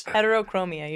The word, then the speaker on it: Heterochromia: I think one is named Heterochroma. – This is Bob. – Really? – But he's got the two different colored eyes. – Heterochromia, you're right. – Heterochromia, I mispronounced it.